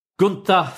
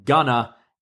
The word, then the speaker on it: Gunther: And then Imperium's music hit and they come out and Gunther says, "You will not speak of Imperium in this fashion." And Bivens once again calls him Gunner. He goes, "It's pronounced Gunther.